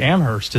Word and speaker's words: Amherst: Now, I mean, apparently Amherst is how you say it.